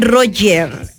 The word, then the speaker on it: Roger: Bueno, se escribe Roger, pero le dicen Roger.